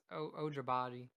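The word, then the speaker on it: Agbaji: I think it's pronounced Ojibaji. – Yeah, you said it right the second time.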